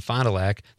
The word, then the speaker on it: Fond du Lac: She says, am I saying that right? – Gillies and Fond du Lac, yes. – Okay, Gillies and Fond du Lac.